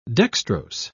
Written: dextrose

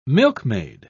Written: milkmaid

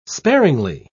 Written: sparingly

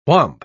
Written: whomp

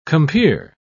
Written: compeer

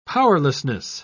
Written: powerlessness

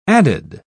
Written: added

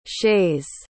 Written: shays

